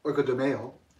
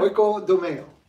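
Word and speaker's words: Oikodomeo (οἰκοδομέω): We're going to be talking about one of my favorite Greek words today, oikodomeo.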